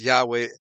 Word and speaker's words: Yahweh: The original name, Yod-Heh-Vav-Heh, was pronounced Yahweh, and there was a U, sometimes, or Yehu, but the U is pronounced like a W, so it's really Yahweh, Yahweh.